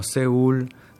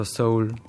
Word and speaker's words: Seul: Tokio z jem pisano namesto z ypsilonom, recimo Seul namesto Seul, Teheran namesto Tehran, Sana namesto Sana'a in tako dalje.